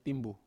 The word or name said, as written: timbu